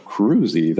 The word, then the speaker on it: Kroese: Like, half the people who know me still pronounce it wrong, because they're like, they look at it, and they go, Kroese, that can't be right. He must be pronouncing that wrong. And so, and honestly, I think I probably am pronouncing it wrong, because it's a Dutch name, and I don't think it's an Americanization of a Dutch name, and so the Dutch wouldn't pronounce it that way anyway, so I can't really say.